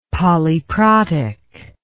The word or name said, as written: polyprotic